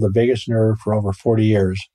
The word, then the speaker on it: vagus: And this is related to the, is it pronounced vagus nerve? – Right.